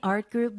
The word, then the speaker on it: art group: I did face painting for students with my art group members. – Art group, art group 이 정도로 들렸는데요. 원래 트 발음은 잘 나지 않습니다.